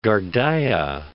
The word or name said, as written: Ghardaia